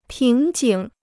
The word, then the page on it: 瓶颈: píng jǐng